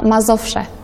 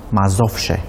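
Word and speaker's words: Mazowsze: că știi să pronunți mai bine numele corului. Mazovse sau... – Mazovse. – Mazovse, așa da. Se spune corect.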